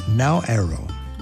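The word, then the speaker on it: Naoero: The people of the Republic of Nauero, that's N-A-O-E-R-O, want the rest of us to know how they pronounce and spell their island home.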